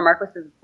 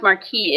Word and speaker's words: Marquis: So whatever a Marquis's wife is. – Right. – It's only pronounced Marquis if you're French, I guess. Otherwise, it's Marquis.